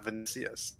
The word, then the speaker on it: Vinicius: The correct pronunciation is Vinicius.